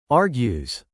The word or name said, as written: argues